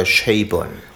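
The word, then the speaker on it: Chabon: Und zwar, er hat gesagt, dass Chi, also das CHA, soll gesprochen werden wie ein Stadion, dessen Namen ich auch nie aussprechen kann. – Das Chi im Chinesischen. – Nee, das Chi, das S-H-E-A-Stadion. Und das, würde ich sagen, wird Che ausgesprochen. Und das Bon soll ausgesprochen werden wie bei Bon Jovi. Also sind wir bei Che Bon.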